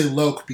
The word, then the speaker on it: SZLOC: I say Loke because it has a little long vowel sound over the O there.